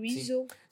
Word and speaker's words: Wiseau: Wiseau. – Sim, a maneira como tu dizes o E-A-U é O. É francês, é uma pronúncia francesa. É o Wiseau.